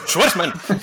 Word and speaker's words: Schwartzman: Jason Schwartzman. ¿Lo he dicho bien? Más o menos. Más o menos. Joder, ha faltado una R por ahí, yo creo. Es, como un estornudo. Schwarzman.